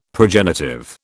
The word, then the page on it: progenitive: British English